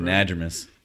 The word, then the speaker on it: anadromous: Is a fish biologist and habitat restoration coordinator at the USFWS's Anadromous Fish Restoration. Did I say it right? – Anadromous.